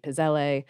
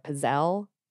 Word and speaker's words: Pizzelle: I'm nearly positive that in the Italian it should be Pizzelle, but I understand that Americans pronounce it both Pizzelle or Pizzelle.